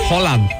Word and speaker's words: Holland: ¿Cómo se dice? – Holland, ¿verdad? – Así se dice.